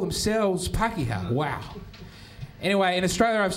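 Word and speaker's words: Pākehā: Pakeha, that's the Maori word, and I'm saying that correctly.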